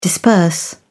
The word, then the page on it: disperse: British English